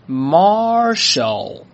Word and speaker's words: marshal: 来，跟我一起念，叫做 marshal，再来一遍 marshal。注意啊，中间是有一个 R 的卷舌的发音的，所以呢是 Marshal，它就可以表示元帅的意思。